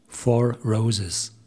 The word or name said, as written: Four Roses